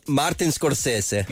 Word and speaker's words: Scorsese: Ja, den er også lidt svær at sige det italiener navn, Det er Mar- Martin Scorsese.